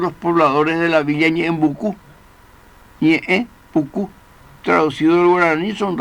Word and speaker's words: Ñeembucú: Y es seguro que si los que dieron nombre a este afluente quisieron manifestar derrame largo, Ñeje, Pucú, y solucionaron la dificultad con el más fácil y más simple, Ñeembucú. Y así seguimos pronunciando todos los pilarenses,